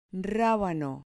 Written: rabano

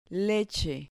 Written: leche

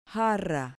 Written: jarra

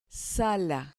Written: sala

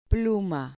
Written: pluma

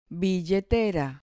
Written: billetera

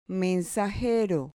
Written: mensajero

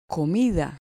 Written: comida

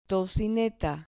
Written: tocineta

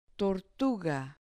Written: tortuga

